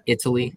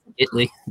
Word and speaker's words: Italy: It's not Italy, it's Italy.